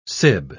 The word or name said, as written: sib